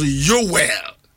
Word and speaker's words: Yoel: Hebrew for number pronounced Yoel. And I say Yoel. The El aspect means God.